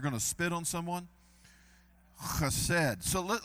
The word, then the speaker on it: chesed: That's how you say it, chesed.